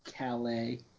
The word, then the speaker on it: Callis: No, that's a reference to Don Callis. Cyrus the Virus in ECW. And is now doing commentary for New Japan Pro Wrestling. – It's pronounced Calais.